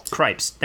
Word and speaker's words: Krieps: Vicky Krieps, am I saying this right? – Cripes.